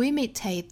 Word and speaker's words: imitate: fail to imitate them。还要注意节奏，就是哒哒哒哒哒哒。 Fail to imitate them. Imitate 这个词重读音节在第一个音节上，所以是哒哒哒哒。